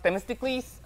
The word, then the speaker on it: Themistocles: It's actually pronounced Themistocles.